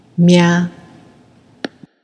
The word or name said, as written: Miah